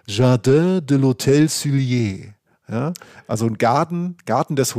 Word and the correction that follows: Sully: Hotels Sully, so sagt man es. Jardin de l'Hôtel Sully.